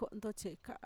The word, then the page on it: ko'ntotxe'eka'a: Mixtec